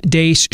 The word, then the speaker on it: Deace: You can follow me on Twitter at Steve Dace Show, and the last name is spelled D-E-A-C-E.